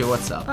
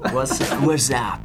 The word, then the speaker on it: WhatsApp: Perché noi diciamo WhatsApp, ma magari non si dice così. – Si dice WhatsApp. – Ah, wow. – WhatsApp.